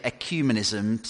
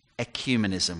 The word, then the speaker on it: ecumenism: Some people say ecumenism, some people say ecumenism, but what should we call it? Satchi, give us the authoritative pronunciation. We're going to call it ecumenism. We're going to go for that.